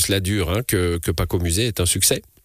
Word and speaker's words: Pakomusé: Paco Musée, ça s'écrit presque comme ça se prononce.